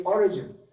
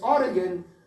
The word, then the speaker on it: Origen: I notice Americans say Origen, presumably to distinguish it from Oregon. But it's Origen, O-R-I-G-E-N, or Origen, whatever.